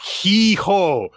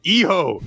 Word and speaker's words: hijo: It's not hee-ho. Hijo. H's are silent in Spanish.